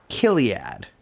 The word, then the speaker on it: Chiliad: And um, Christine goes on to found a company, or goes on to partner with a company called Chiliad. – Yeah, sometimes pronounced Kiliad, oh. which gives me a little bit of a shiver.